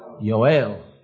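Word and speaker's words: Yoel (יואל): How many know how to pronounce Joel in Hebrew? Yoel. Yoel.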